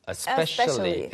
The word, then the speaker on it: especially: 다시 뒤로 돌려서 아, a specially가 아니고 especially, especially였구나. Mm-hmm. 이런 경우 이게 어떤 사람은 e에 더 가깝게 발음할 때가 있고 어떤 사람은 어에 더 가깝게 발음할 때가 있는데 uh-huh.